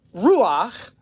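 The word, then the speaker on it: ruach (רוּחַ): This word ruach carries this idea of, I'm going to write it on the board actually. It's actually R-U-A-H, but it's ruach is how you say it.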